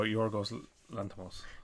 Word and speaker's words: Yorgos Lanthimos: Yorgos Lanthimos. I don't know how to say his name. – Oh, Yorgos Lanthimos.